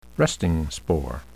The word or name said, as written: resting spore